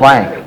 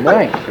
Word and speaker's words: blanc: That's called a carte blanche. – Actually, the French would pronounce it blanc. – Blanc. – They don't use it for potatoes. – The Grandites say blank.